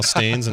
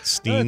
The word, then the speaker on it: Berenstain: No, it is A-I-N. – Oh, is it? Bears. But it was never pronounced that way when I was growing up. This is another thing that changed that I didn't know about. They're now Stains and not Steens.